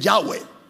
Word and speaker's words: Yahvé: Otros lo pronuncian Yahvé, porque en sí nadie sabe. Otros dicen Yahweh, porque en sí nadie sabe exactamente cómo se pronuncia.